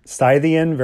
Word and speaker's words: Scythian: Barbara read that word Scythian very well, perfect pronunciation.